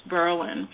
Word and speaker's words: Berlin: And it looks like Berlin, Germany, but it's pronounced Berlin.